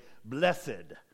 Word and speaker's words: blessed: If you grew up in church, we usually, especially in Texas, we add another syllable to it, don't we? We say, blessed. All right? I'm blessed are those. Blessed are the poor in spirit. You can say it either way.